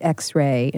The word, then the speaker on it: EX:RE: It's EX:RE, again pronounced X-Ray, and it's a deeply personal record that was made with both a sense of urgency and a cathartic need.